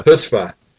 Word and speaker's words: Chutzpah: Chutzpah.